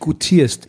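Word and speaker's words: goutierst: Meinst du, ein Bildungsdefizit, an dem du dich gutierst. – Ja, aber und das war jetzt richtig ausgesprochen. Gutieren. – Gutierst war sehr gut.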